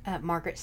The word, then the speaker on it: Margaret: Margaret Sowers. – We spell it as well, please. – My whole name. M-A-R-G-A-R-E-T.